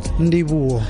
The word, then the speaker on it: Ndivhuwo: Ndivhuwo.